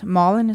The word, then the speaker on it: Malin: My first name is Malin, and nobody can pronounce it correctly. – Malin? – Now the secret is out. Malin is my name. – M-A-U-L-I-N? – M-A-L-I-N. All it's right. A Swedish name.